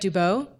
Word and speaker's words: Dubeau: I said J.F. Dubeau. – That's perfect.